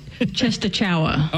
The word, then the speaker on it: Częstochowa: It looks like Częstochowa.